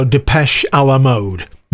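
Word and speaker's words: Depeche Mode: And she was the one that told me how to pronounce that properly. So Depeche à la mode.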